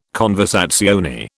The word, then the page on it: conversazione: British English